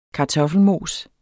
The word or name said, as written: kartoffelmosen